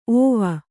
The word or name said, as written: ಓವಿ